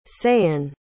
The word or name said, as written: sahen